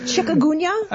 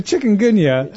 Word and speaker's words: chikungunya: Chikungunya? – Chikungunya.